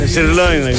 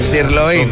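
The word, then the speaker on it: sirloin: Πώς προφέρεται? – Σιρλόιν. – Σιρλόιν.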